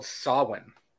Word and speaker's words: Samhain: It's actually called Samhain. That's how you pronounce it.